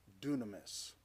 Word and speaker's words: dunamis: The word is dunamis. It's spelled with a Y, so it's more obvious there that we get the word dynamic or dynamite from it.